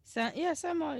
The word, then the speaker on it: Saint-Malo: Saint-Malo, yeah.